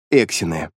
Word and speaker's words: эксины: А та была написана не только на множестве земных языков, но и на 3 тех, на которых говорят эксины. Робот оказался экспортно-сувенирной моделью. Слово «эксины» прижилось, так как с ударением на первую гласную легко произносится и людьми, и чужими.